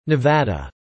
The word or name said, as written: Nevada